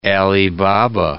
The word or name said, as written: Ali Baba